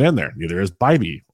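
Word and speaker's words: Bibee: We're waiting for Bibby. I always mispronounce it. Bibby. Sorry, it's Tanner Bibby. That says Bibby. My apologies.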